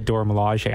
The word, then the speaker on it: Dora Milaje: I feel like Okoye is a better, who is like the leader of the, how do you pronounce it? – The Dora Milaje? – The Dora Milaje.